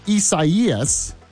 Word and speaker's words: Isaias: And number one, we have some of the latest news on this hurricane, Isaias, which, yes, apparently that is the correct way to pronounce it.